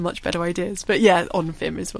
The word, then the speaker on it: Onfim: And I think, just tell us about, I don't know how to pronounce the name, Onfim? – Oh, little Onfim, yes. – Onfim.